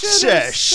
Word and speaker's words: Sesh: You say your name is Sesh like a... – Yes, Sesh. – Sesh like a Szechuan sauce.